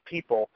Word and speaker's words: Peeple: And when we're talking about this site called People, it's spelled P-E-E-P-L-E. So when you hear the word people in this podcast, that's what I'm talking about. I'm talking about a website, P-E-E-P-L-E.